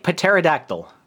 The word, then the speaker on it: pterodactyl: Because when you have a P before the T, the P is silent. Like pterosaur. – Pterosaur. Like pterodactyl.